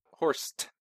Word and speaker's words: Horst: Horst. Gotta make sure I pronounce the T.